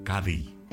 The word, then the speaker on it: Cádiz: La interpretación que escuchamos corresponde al coro de Julio Pardo, una de las agrupaciones más emblemáticas del carnaval de Cádiz, o como dicen en Andalucía, o pronuncian, Cádiz.